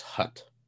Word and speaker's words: Hutt: It's pronounced Hutt.